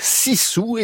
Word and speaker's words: sisu: Ça s'écrit S-I-S-U, mais avec la prononciation finlandaise, ça donne donc Sissou,